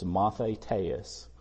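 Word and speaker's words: mathetes (μαθητής): The word used here is mathetes, although it's spelled like mathitis in the Greek, and all it means is learner.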